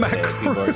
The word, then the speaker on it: Macron: He sent an official letter to French President Emmanuel Macron, or Macroon. I don't know how to pronounce Macron. – It. Macroon. – You put some extra O's in there.